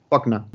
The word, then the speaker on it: Faulkner: Pues ya que has hablado de, de Faulkner, o como lo pronuncias tú, que no sé cómo lo pronuncias, Faulkner o Fogne. Faulkner.